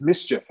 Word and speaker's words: MSCHF: And so I think another really cool company that I think is the next big thing is Mischief. Have you been following Mischief? – I think I've seen it, but I don't remember the details. – So basically it's spelled MSCHF.